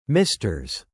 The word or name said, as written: misters